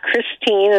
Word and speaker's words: Christine: Then uh, Christine